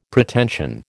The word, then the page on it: Pretension: American English